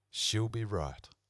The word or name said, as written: she'll be right